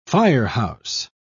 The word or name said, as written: firehouse